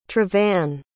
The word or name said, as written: trevan